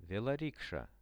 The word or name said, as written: veloriksha